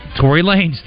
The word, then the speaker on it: Tory Lanez: Do you know this guy, Tony Lanez? Lanez? – Tory Lanez? – Tory Lanez, thank you very much.